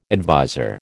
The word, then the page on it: adviser: American English